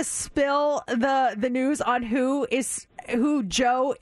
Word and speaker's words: Djo: I will tell you the artist of that song is Joe. D-J-O. That's how you spell it. D-J-O. The D is silent. Joe.